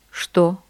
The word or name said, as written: что